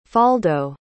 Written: faldo

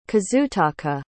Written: kazutaka

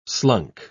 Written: slunk

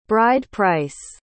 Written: bride-price

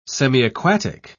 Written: semiaquatic

